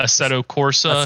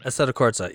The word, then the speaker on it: Assetto Corsa: So see cuz like so in uh in uh, Assert, uh, a Corsa, how did you how do you pronounce it again? uh, Assetto Corsa Assetto, and er, er, Assetto Corsa Competizione. – Assetto Corsa, yeah.